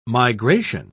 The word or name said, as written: migration